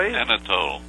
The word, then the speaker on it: Anatole: Anatole.